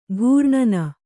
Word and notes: ಘೂರ್ಣನ: Kannada

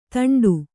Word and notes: ಥಂಡಿ: kannada

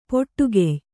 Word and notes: ಪೊಟ್ಟುಗೆಯ್: poṭṭugey

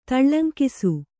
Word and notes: ತಳ್ಳಂಕಿಸು: Kannada

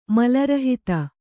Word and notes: ಮಲರಹಿತ: mala rahita

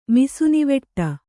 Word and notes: ಮಿಸುನಿವಟ್ಟೆ: misunivaṭṭe

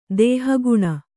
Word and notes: ದೇಹಗುಣ: dēha guṇa

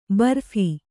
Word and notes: ಬರ್ಫಿ: barphi

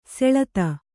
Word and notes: ಸುಳಾದಿ: suḷādi

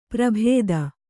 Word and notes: ಪ್ರಭೇದ: prabhēda

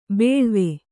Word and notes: ಬೇಳ್ವೆ: bēḷve

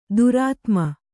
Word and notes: ದುರಾತ್ಮೆ: Kannada

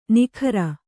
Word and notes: ನಿಗರ: kannada

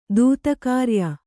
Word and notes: ದೂತಕಾರ್ಯ: Kannada

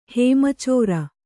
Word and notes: ಹೇಮಚೋರ: kannada

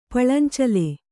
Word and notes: ಪಳಂಚಲೆ: paḷancale

